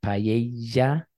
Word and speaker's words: paella: Now the double L makes a Y sound, a y, y, y, or often a soft J, like paella.